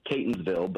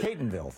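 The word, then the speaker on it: Catonsville: It's Catonville, but Catonville, thank you. – Everybody mispronounces it, so I take no offense.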